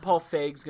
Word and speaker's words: Paul Feig: Or is it Feig? – Is it Feig? – Why can't anybody say his name? – Why can't people just say it? – It's definitely Paul Feig, and I hear people that should know his name mispronounce it.